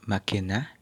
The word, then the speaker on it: macchina: First one is cercare, cercare, and the second one is macchina, macchina. In the word macchina, there is a C and an H together but it's pronounced ki, like in Cookiano.